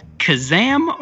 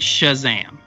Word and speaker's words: Kazam: Is it Kazam or is it Shazam? – With a K.